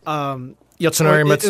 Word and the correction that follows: Yasunori Mitsuda: Oh, it was yes, it was Uematsu's birthday, but this is of course Yasunori Mitsuda, one of the handful of really top notch like video game composers like Koji Kondo, Nobuo Uematsu, and Grant Kirkhope.